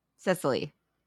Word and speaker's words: Cecily: Like, is it I forgot how her name's pronounced. Is it Cecily? – Cecily.